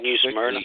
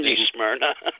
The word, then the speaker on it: New Smyrna: We're talking about the K&M Pro Series starting down there in New Smyrna, how you pronounce it. It's just hard to see how New Smyrna. – Everything. – New Smyrna.